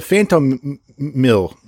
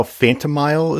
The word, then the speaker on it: Phantomile: Do we know how Phantomile is pronounced? – That sounds about right. – That's how I would have pronounced it. It's like the word phantom and the word mile with an M missing, right? – I guess we'd have to look at it.